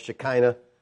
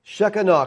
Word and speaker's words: Shekinah: You've heard it pronounced Shekinah. Shekinah cloud.